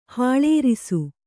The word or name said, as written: ಹಾಳೇರಿಸು